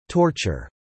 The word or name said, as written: torture